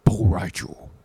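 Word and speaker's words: Bo' Rai Cho: Is that, are you pronouncing it right? Bol Rai Cho. – Yes. Look it up right now if you want.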